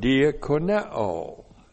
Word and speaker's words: diakoneo: D-I-A-K-O-N-I-A. Diakonia. All right. And point one, diakonia comes from a verb, diakoneo. Looks like that in the Greek, D-I-A-K-O-N-E, long O. Diakoneo.